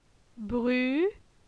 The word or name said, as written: Bru